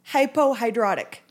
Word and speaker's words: hypohidrotic: you have hypohidrotic ectodermal dysplasia. Did I say that right? – Very close. Hypohidrotic. – Hypohidrotic.